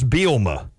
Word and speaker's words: Bielema: It is Bielema. is how you say it. That second E is silent.